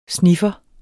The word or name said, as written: sniffer